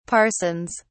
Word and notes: parsons: British English

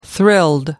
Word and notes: thrilled: American English

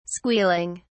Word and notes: squealing: British English

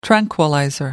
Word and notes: tranquilizer: British English

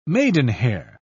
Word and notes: maidenhair: British English

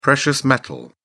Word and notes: precious metal: British English

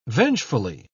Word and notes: vengefully: British English

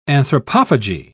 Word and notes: anthropophagy: British English

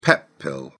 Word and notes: pep pill: British English

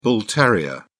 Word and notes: bull terrier: British English

